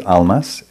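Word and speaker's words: Almaas: So his book name is Almas, A-L-M-A-A-S, A-H Almas, but he's often, was also called Hamid, and founder of the Diamond Approach work.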